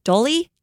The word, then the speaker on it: dholi: And part of the time she was carried, and I don't know how to say this, but I would pronounce it Dolly. D-H-O-L-I.